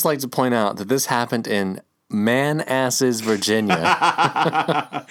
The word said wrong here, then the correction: Manassas: It's pronounced Manassas. – Oh, Manassas. But yes, it is Manassas.